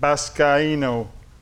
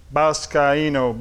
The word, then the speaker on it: baskaino: Baskaino, B-A-S-K-A-I-N-O. Baskaino. Baskai. Anytime you see A-I in a Greek word or in the Bible, really, it comes from Greek, you pronounce it I. You hear me? You pronounce the I like Achaia, A-C-H-A-I-A. The A-I is A-E, A-E, like they do in Spanish. A-E, Achaia. That's how you do it.